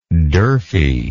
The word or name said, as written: durfey